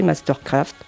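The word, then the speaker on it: MSTRKRFT: comme Mastercraft qui devient MSTKRFT, toujours prononcé Mastercraft, ou MGMT pour Management.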